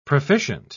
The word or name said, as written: proficient